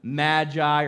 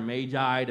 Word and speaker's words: magi: Some of your translations call them magi or magi, depending upon where you are in America and how you say it.